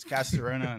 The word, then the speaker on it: Castorena: It's Castorena in English.